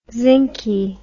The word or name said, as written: zincy